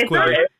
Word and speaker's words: Quibi: This is like, I don't even know the tangents we're going on, but you know, like that QB shit. – I thought it was Quibi. – It's Quibi. Okay, I was saying it wrong,